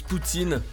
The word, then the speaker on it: poutine: Ah, le Canada, son sirop d'érable, ses magnifiques paysages, sa fameuse poutine. Joli nom par ailleurs. En fait, ça se prononce Poutine, mais pas Vladimir Poutine.